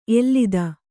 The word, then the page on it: ಎಲ್ಲಿದ: ellida